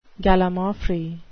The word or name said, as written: gallimaufry